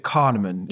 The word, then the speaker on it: Kahneman: Dr. Daniel, is it Kahneman? – Yes.